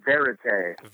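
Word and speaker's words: VÉRITÉ: Was it Verite or Verite? It had the accents. So for once and for all, can you settle how to pronounce that name? – Yes. – It is Verite.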